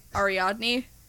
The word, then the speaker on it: Ariadne: I know what the character's name is, I'm worried about mispronouncing it, Ariadne.